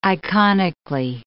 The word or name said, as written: iconically